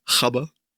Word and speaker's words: Gabber: Die Doku heißt Die neuen Gabber Hardcore Never Dies oder wie es die Holländer so schön aussprechen, Chabba.